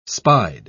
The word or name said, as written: spied